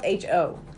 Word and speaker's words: Ho: It's spelled H-O. There's no P in it. I looked it up. It's Ho.